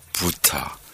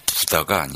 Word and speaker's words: Butar: 노래도 아주 멋진 곡인데 어, 여기에 나오는 아디나 부타 아, 부다가 아니고요. 아, 석가 보니 부, 부처, 부처랑 비슷한 발음인데 아디나 부타라는 여성 보컬 어,